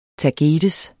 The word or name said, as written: tagetes